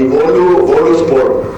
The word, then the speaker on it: Boluspor: Y sí, se pronuncia exactamente así, Boluspor.